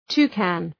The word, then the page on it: toucan: ‘tu:kən